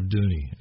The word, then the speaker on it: Dooney: I want to talk to you about I want you to introduce, and this tune, The Fiddler of Dooney, and that's how you say it. There's two O's in it that have that sound.